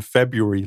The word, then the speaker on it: February: Oh, I just have to laugh at that R in February, like it's an silent R.